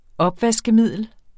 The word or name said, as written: opvaskemiddel